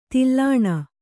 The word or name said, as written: ದಲನ